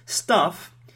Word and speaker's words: stuff: Stuff is things, and there is a vowel sound which is short a, stuff.